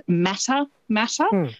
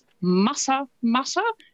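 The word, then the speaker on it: Matamata: Quite often, because of how it's spelt, people will pronounce it Mata Mata, but it's Mata Mata, which is, I think, a lovely way to say it.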